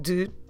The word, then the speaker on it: de: soft de. Don't try to pronounce that last E at the end, okay? Just do the sound of the consonant de, and you can do your dates, as datas.